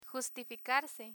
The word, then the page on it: justificarse: Spanish